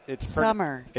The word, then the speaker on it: Somer: It's Summer. – It's pronounced like the season, but it's spelled S-O-M-E-R. – Hmm. Ooh. – Yeah. – We have a Summers, Montana. – With the S-O-M-E-R? – With an S at the end.